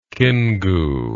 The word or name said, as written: Kingu